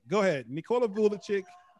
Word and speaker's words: Vucevic: I think uh Voychich is oh I probably butchered his name. Nick, it's Again. – Nikola Vucevic. – I had I, it came to me as soon as I like disconnected. I was like, it's Nikola Vucevic.